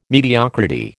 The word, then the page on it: mediocrity: American English